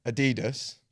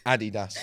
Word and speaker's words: Adidas: If you're from America, you're probably pronouncing the sneaker brand Adidas. Mm-hmm. That's completely wrong. It's Adidas.